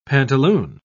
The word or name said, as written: pantaloon